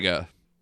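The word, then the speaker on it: Negga: But what draws you in is the relationship between Tessa Thompson and Ruth out of Nega. I don't know how to pronounce her last name. – Nega.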